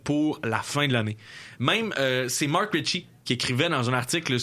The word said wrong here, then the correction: Recchi: Mark Richie ou Mike Richie? – Richie, R-E-C-C-H-I, là? – Mark Recchie. – Recchie, excuse-moi.